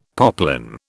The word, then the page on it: poplin: British English